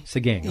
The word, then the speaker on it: Sagaing: Sagaing, okay.